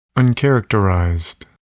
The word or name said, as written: uncharacterized